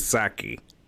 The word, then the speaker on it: Psaki: Her first name is Jen. Her last name is Psaki. Jen Psaki.